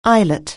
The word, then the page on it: eyelet: anglais